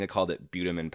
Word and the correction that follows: bitumen: powder in the last episode. It's actually pronounced bitumen powder.